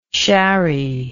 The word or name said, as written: sharrie